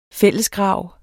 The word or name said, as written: fællesgrav